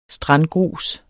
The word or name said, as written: strandgrus